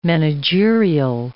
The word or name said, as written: managerial